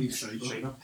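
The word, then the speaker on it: Liev Schreiber: Leif Schreiber.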